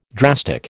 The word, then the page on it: drastic: American English